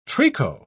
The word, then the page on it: tricot: British English